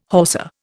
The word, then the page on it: Hausa: Indian